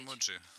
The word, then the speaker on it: эмодзи: Эмоджи. – Да, вполне возможно. Оно уже есть в академическом орфографическом словаре русского языка в Академосе. Именно с таким ударением закрепилось, хотя были колебания.